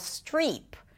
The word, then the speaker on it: Streep: Now, her name is pronounced Meryl Streep. Strip is a different word.